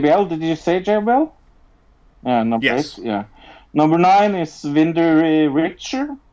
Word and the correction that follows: Wendy Richter: Oh, Wendy Richter.